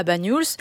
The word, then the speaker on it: Banyuls: Comment on le prononce ? – Banyuls. – Banyuls. – Oui, ouais. C'est ça.